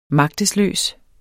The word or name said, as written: magtesløs